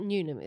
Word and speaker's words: Newnam: You said Jacaster right, but Newnam is the...